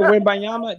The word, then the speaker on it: Wembanyama: Well, you know they've been there was reports that they have already talked to let me try to pronounce this kid's name. Wimba, what is it? Wimba Nob, man. – Yeah, Wim. Victor Wembanyama.